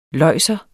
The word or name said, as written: løjser